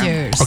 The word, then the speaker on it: Avengers: Avengers.